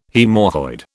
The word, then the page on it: haemorrhoid: British English